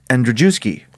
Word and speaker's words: Andrzejewski: This name, A-N-D-R-Z-E-J-E-W-S-K-I.